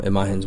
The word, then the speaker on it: Imagen: I'm going to say it looks like Imagine, but it's not spelled Imagine. – It's pronounced Imagen Award.